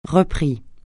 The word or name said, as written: repris